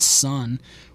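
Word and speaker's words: Sunn: Sun O, properly pronounced Sun,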